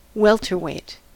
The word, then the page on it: welterweight: American English